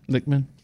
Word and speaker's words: Lichtman: Lichtman.